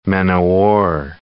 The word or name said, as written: men-o'-war